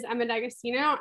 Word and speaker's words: Emma D'Agostino: So my name is Emma D'Agostino, and I'm really proud that you pronounced that so well.